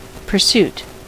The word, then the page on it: pursuit: American English; British English; /pəˈsjuːt/; /pɜːˈsjuːt/; /pɝˈsuːt/